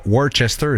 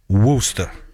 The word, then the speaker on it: Worcester: C'est pas Worcester, c'est Worcester. Ils prononcent ça de même.